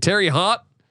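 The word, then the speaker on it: Terre Haute: I'm probably butchering it. Terry Haught. – Okay. – H A U T E. Right.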